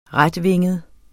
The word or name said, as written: retvinget